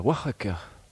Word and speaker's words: Oaxaca: La dernière fois, nous étions à Oaxaca. On se dirige donc du côté de Monte Alban. – Vous le prononcez admirablement, Oaxaca. – Sans rouler le R et tout.